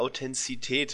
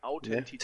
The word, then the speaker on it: Authentizität: mit der Authentizität zu tun. Habe ich es richtig gesagt? Ich hasse dieses Wort. Nein, Authentizität. – Authentizität, ne? ja, genau. Danke.